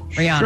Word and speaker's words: Rihanna: Rihanna. Did I say it right? Rihanna.